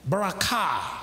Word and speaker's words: barakah: So the word blessing, or the word blessing, is from the Hebrew word barakah. Barakah. I spell it B-A-R-A-K-A-H. The accent is on the K-A-H.